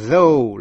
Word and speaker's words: though: O-U-G-H còn phát âm là O trong chữ though là tuy nhiên, dẫu vậy. GH cầm. T-H-O-U-G-H.